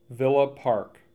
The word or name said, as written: Villa Park